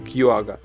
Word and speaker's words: Quagga: Я думаю, что это читается как Quagga.